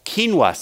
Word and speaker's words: quinoa: Then we have some baked Dijon mustard chicken breast on a bed of quinoa seeds. I was saying quinoa last night. It's two syllables, right? Quinoa.